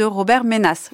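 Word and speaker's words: Robert Menasse: Et votre traduction parue hier aux éditions Verdier de Le Monde de Demain, une Europe souveraine et démocratique et ses ennemis de Robert Ménas. Je le dis bien aussi là ? – Tout à fait.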